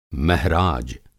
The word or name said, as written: महराज